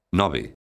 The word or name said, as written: nove